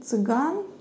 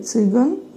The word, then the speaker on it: цыган: И вот в этом фильме Кустурицы а, был один такой а, цыган или цыган, тут на любителей, у кого как а, ударение разговорное или литературное.